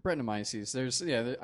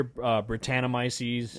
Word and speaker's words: Brettanomyces: Brettanomyces. – Brettanomyces. I've heard a couple different pronunciations, but I typically hear standard. Yeah, I typically hear Brettanomyces.